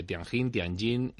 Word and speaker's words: Tianjin: Tianjin, que significa vado, vado celeste o algo así, un paso hacia el cielo, paso del emperador, también puede traducirse.